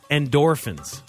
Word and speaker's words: endorphins: Well, I think endorphins is how I pronounce it. – And I said endodorphins? – Yeah. – Yeah, I probably added a syllable there. – That's okay. – Endorphins.